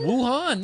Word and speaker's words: Wuhan: Wuhan? – Wuhan.